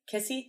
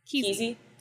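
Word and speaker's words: Kesse: I found it. It's Key Z. – Keezy. Keezy.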